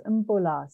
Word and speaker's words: bula: It's pronounced Mbula. So the M is soft, but it's B-U-L-A, and that means hello and good health.